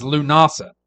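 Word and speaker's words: Lughnasadh: The third is Lunasa. It's spelled Lughnasad, but it's pronounced Lunasa.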